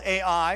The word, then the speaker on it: Ai: That's spelled A-I, or A-I is how you pronounce it.